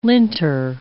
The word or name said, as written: linter